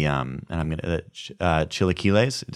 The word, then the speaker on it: chilaquiles: If you're gonna get the burrito, you have to get the chilaquiles.